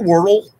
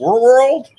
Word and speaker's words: Wordle: Do you do Wordle? – Did you say it right? – I said Wordle. – World.